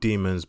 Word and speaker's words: daemons: They're called demons, but it's spelled daemons.